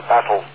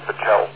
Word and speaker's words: Battelle: Yeah, some of the confusion is that you're pronouncing it as a battle. It's Battelle.